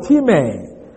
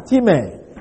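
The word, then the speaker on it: timē (τιμή): Now, the word honor is the Greek word timē, spelled like our English time, timē. In a long way, we spell it T-I-M-E-E, timē.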